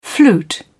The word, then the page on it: flute: British English